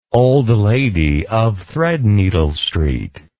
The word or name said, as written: Old Lady of Threadneedle Street